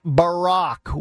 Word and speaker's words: Barack: Barack Obama, Barack Obama, it's, with all due respect, doggy, it's Barack. We've all figured out how to say it correctly, yet that guy still can trip over the easiest words of all time.